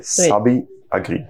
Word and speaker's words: Sabiagri: The name is pronounced S-A-B-I-A-G-R-I. Sabi Agri. Sabi Agri.